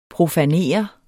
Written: profanere

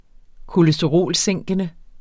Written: kolesterolsænkende

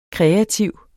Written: kreativ

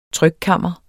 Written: trykkammer

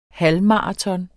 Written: halvmaraton

